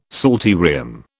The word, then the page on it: psalterium: British English